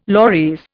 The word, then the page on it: loris: Indian